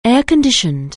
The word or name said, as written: air-conditioned